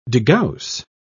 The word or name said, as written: degauss